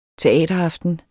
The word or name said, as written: teateraften